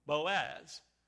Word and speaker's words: Boaz: So Boaz, and it's pronounced, you know, Boaz, like this. Say in the Greek. I mean in the Hebrew. Bo, and then as. And then the second one he named, spelled this way, J-A-C-H-I-N.